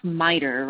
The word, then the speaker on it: MITRE: I thought you had been here before, but Chris from, it's pronounced MITRE, right? – That's right, yes.